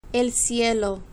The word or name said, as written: el cielo